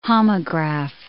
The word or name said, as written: homograph